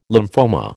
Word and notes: lymphoma: American English

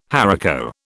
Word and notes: haricot: British English

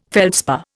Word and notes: feldspar: Indian

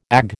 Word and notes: Ag: American English

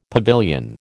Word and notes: pavilion: American English

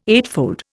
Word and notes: eightfold: Indian

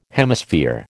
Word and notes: Hemisphere: American English

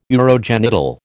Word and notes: urogenital: American English

